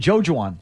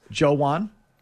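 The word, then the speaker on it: Joejuan: Okay, it's spelled J-O-E-G-J-U-A-N, Joe Juwan. – Joe Juwan. – J-U-A-N is Juan, like Juan Uribe or Juan Gonzalez. – Joe Juan.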